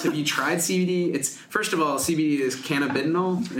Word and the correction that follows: cannabidiol: Is that correct? – I guess. – How do you say it? – This is my pronunciation is, now I'm going to say it wrong. cannabidiol. – Cannabidiol. Okay. – Yes. This is the Canadian pronunciation that you hear at the medical conferences.